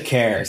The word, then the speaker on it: Kerr: Alexa Care. So it's A-L-E-X-A, Alexa. And then her last name is Care, K-E-R-R. Pretty sure that's how you say it.